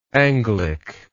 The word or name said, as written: Anglic